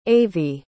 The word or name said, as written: avye